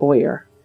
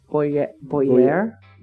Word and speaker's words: Boyer: Boyer. Not Boyer. Boyer. – Mm-hmm. – He's French, I've been told.